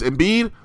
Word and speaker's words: Embiid: And then it is, it's Embiid. The next 3 performances are Wilt Chamberlain performances. No, I said it right the first time.